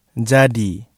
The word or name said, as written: jadi